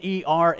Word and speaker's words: Er: The first one, his name is Ur, but it's spelled E-R, and I really like to pronounce it Er because he was full of errors.